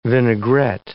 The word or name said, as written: vinaigrette